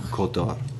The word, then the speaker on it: Côte d'Or: There's the Gold Coast in Australia, and they call it um, how do you say that in French? Côte Côte d'Or. Côte d'Or. Right. Thank you.